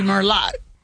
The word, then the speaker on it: Merlot: It'd be Merlot. It says Merlot. – I guess so, yeah. – Yeah, remember Merlot is, and that T is silent the T on the end silent. Of Merlot.